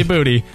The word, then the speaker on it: Booty: No, it's got to be spelled like B-E-W-T-Y. – It's definitely Booty. – Oh, anyway, yeah, it's B-O-O-T-Y.